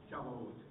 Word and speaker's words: Shavuot: Now, Pentecost, hopefully I pronounced it correctly, Shavuot? – Shavuot. – Yeah, what he said.